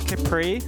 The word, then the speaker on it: Kypri: Is it Kypri or Kipri? How do you pronounce it? – I think it's Kipri. – Kipri. – Short Y.